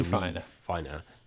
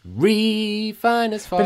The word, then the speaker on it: refiner: And one comment is that it puts the emphasis on the wrong syllable. You don't say a refiner, God is a refiner. – You say God is a refiner. But he goes, refine as fire.